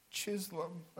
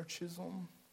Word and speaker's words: Chisholm: There was a man by the name of Thomas Obadiah Chisholm, or Chisholm. It sounds kind of sweet. His last name is spelled C-H-I-S-H-O-L-M.